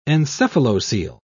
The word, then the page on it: encephalocele: British English